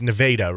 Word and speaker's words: Nevada: It is spelled the exact same way as Nevada, N-E-V-A-D-A, Nevada. That's the name of the town. – Okay. – Give you a guess how you pronounce the name of that town. – Well, I do know how. It's Nevada, right? – It's Nevada, yes.